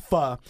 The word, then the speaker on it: pho: You can get all sorts of great Vietnamese dishes there, including pho, which is a lot of people pronounce pho, yeah. – But it's pronounced pho.